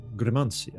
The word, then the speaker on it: Gremanci: And Gremanci, I believe is the pronunciation.